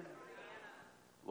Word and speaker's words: Rihanna: Rihanna, how do you say her name? – Rihanna. – What? – Rihanna.